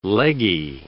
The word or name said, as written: leggy